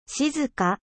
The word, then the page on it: silencieux: French